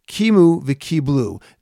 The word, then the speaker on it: Kimu v'kiblu: The way that it's pronounced is indeed the way that I said it. Kimu v'kiblu.